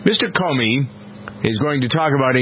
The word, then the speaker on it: Comey: The latest, this is interesting, in the first major policy speech, the new director of the FBI, James, is it Comey? I think that's it, Kenny, right? Comey? How you pronounce his name? C-O-M-E-Y.